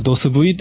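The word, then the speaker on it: DOS/V: 漢字ロムって言って売ってたりしましたよね。ドススラッシュ V と書いて、読みとしてはドス V と言いますけども、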